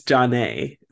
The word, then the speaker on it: Jonna: Is her name spelled like Jonna? – Yes. – Jonna? – But it's John A. That's how you say it.